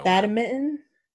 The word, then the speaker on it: badminton: I call it Batman, but apparently that's not, it's Batmitten. – Wait. Say your pronunciation again, please. – Batman. Batman. It's Batmitten. – Badminton? – Batman. – B-A-D-M-I-N-T-O-N. – Badminton? – Yes.